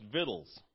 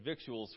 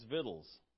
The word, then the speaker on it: victuals: I guess that's actually pronounced victuals. I said victuals for a long time, but it's victuals.